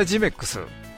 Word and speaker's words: GYM-EX: な施設ということでね、えー、来年のハンフェア 2024 は 8 月の 24 日から 25 日に有明、えー、 GMEX ですね有明 GYM-EX、うん、ということであのここの GYM-EX で、ね、あの地図にも載ってたりしますのでまあ読み方は GMEX だそうです。